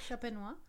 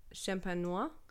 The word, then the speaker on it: Champenois: the Champagnol. Wait, I'm not going to pronounce that right. – Champenois? – Champenois? Champenois? The Champagne makers.